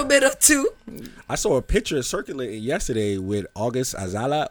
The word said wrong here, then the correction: Alsina: August Alsina.